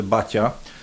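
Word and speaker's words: Baťa: tak to się e, chyba mówi bacia. – Bo to się mówi tak naprawdę bacia e,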